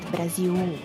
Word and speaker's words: Brasil: So the L at the end of the word is pronounced as U, but we don't really stress the U, so it's not Brasil, but it's Brasil, like it's like a diphthong, so it's like Brasil.